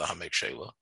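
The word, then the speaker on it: Ha'amek She'elah: I saw there's a question exactly how to pronounce that, Hamek uh, She'ela is the way it's usually pronounced, the Hamek She'ela.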